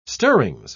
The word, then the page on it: stirrings: British English